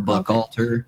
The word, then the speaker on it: Buchalter: His nickname was Lepke, but uh, it's usually pronounced Buchalter or Buchalter. Buchalter. Okay, that's better. Yeah.